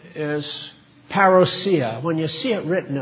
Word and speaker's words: parousia: Ordinarily, I don't mind anglicizations of words, but that's too much for me. So we'll stick with parousia. That's the way it's supposed to be pronounced.